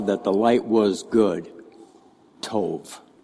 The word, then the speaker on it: tov: Looks like tob, but it's pronounced tov. And it's spelled T-O-V in modern Hebrew.